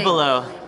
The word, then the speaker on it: Abelow: So pronounce that last name. – Abelo.